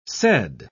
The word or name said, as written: said